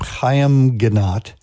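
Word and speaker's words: Haim Ginott: And no, that was not static in your radio. That is how you pronounce his name, Chaim Ginat.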